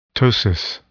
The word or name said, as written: ptosis